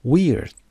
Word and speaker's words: we're: We're. میرین تلفظ we're دقت داشته باشید بچه ها. دوستان پیام میدن و میگن we're و یا where. We're. We're students. چرا؟ چون این کلمه we هست.